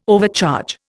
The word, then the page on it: overcharge: Indian